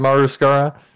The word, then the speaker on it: Maru Skara: Am I saying that right? Maru Skara? – Yeah.